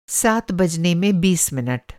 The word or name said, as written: बजना